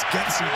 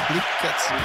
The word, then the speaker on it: Getsy: And by the way, it's Getsy, Jim. Luke Getsy.